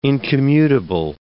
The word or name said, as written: incommutable